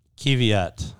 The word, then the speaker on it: Kvyat: Is it Daniel Kvyat? – Kvyat.